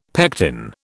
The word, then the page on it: pectin: British English